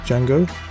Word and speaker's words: Django: Here is a track by John Legend from Quentin Tarantino's brand new movie entitled Django. The D is silent.